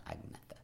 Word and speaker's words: Agnetha: Agnetha. – Agnetha? – Agnetha.